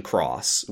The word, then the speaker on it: χ: And then also in 2017, they started up a sequel called Union Cross, which is the same acronym, K-H-U-X. The X is now pronounced cross instead of key.